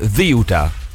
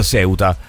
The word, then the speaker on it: Ceuta: In spagnolo della Spagna si dice Ziuta. – Ziuta. – Ziuta. Se poi lo volete pronunciare in spagnolo ispanoamericano, diciamo sì, così, o italianizzandolo, diventa Ceuta.